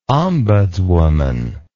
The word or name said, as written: ombudswoman